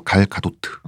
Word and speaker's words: 갤 가돗트: 원래 발음은 이쪽이 더 가깝대요. 갤 가돗트.